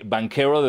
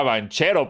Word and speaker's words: Banchero: Ahorita, perdón, ahorita me acordé, no tiene nada que ver con esto, pero en los comentarios me hicieron saber que banquero de Duke sí se pronuncia banquero porque es italiano.